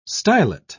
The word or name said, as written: stylet